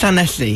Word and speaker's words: Llanelli: Oh, Llanelli. – Alan? – It's getting better, actually. It's Llanelli.